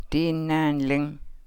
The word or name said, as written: dii naanlang